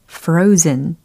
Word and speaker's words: frozen: F로 시작하는 그리고 R O Z E N 이라는 철자이죠. 발음을 한번 해 보세요. frozen 아니고 frozen 이렇게 해 주시면 되겠어요. frozen 이거 아니고요. frozen 모음이 이중 모음이었어요.